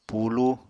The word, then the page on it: pulúu: apatani